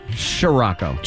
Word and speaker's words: Scirocco: A 1982 Scirocco, is that how you say it? A WV car? – Yeah. Scirocco. – Scirocco. – Scirocco. It's S-C-I-R-R.